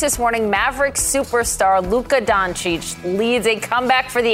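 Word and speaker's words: Dončić: Of course I know how to pronounce Doncic.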